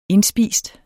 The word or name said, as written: indspist